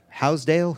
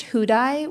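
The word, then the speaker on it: Houdaille: Called Housedale? Housedale? – It's pronounced Houdai, which Houdai. Is not how it's spelled.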